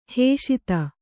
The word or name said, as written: ಹಸಿತ